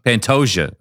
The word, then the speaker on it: Pantoja: Alexandre Pantoja. Okay. I didn't know if it was a silent J.